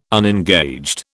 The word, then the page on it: unengaged: British English